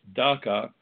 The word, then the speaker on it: DACA: There I was in front of the cameras with Heather uh, Childers to discuss DACA. I jokingly told the uh, folks in the green room that DACA rhymes with caca, and for good reason.